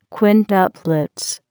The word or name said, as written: quintuplets